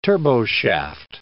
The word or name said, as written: turboshaft